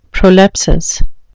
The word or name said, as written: prolepsis